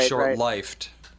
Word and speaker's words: short-lived: It's supposed to be pronounced short-lived, not short-lived. – It's like short-lifed.